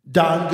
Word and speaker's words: Don: And then my black brothers and sisters go, Dion, D-Ray, like they want to give me extra syllables. Like, dude. – If you were in Florida, yes, they put like a lud. – They put a lud. – So black folks give me too many syllables, white folks don't give me enough.